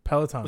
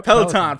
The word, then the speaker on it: Peloton: Peloton. – Was that right? – Yep. – Peloton.